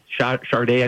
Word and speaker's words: Sade: Sade, Sade, I think is how you say that.